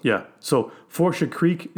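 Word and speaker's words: Fourche: Forsha.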